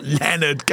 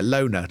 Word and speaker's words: Lona: Not Leonard Cohen, Angela. Leonard Cohen. How do you say it? Lona, not Leonard.